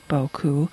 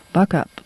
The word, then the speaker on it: Beaucoup: There's a little stream in Southern Illinois, and it is spelled B-E-A-U-C-O-U-P, Boku, although the locals say it, buck up, buck up crick.